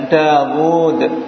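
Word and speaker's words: Dawud (داود): Tapi membacanya seakan-akan di sini ada wawu. Ya, Dawud. Jadi dimatkan Dawud. Paham? Jadi di sini seakan-akan ada wawu tapi hilang.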